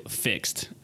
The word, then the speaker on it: FIXD: Have you ever heard of Fixed? – Never in my life. – Oh my gosh. Okay. – How do you spell it though first? – F-I-X-D. – So not F-I-X-E-D. – No, it's F-I-X-D.